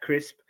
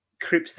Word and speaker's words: Cripps: Um, Yes, I'm, I'm slowly learning how to say his name. It's not Jack Crisp. Um, my prediction was for Cripps to go big, and he didn't really.